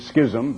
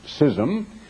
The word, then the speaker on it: schism: And by the way, for you who pronounce it schism, better look it up in the dictionary. It is. The dictionary is pronounced schism.